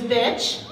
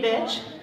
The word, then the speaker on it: Bitch: Anyway, as the beautiful gentleman who introduced me, Steve, said, my name is Bitch. Thank you very much. It's great to be here. I like to say it rhymes with witch. And no, my mother did not name me Bitch.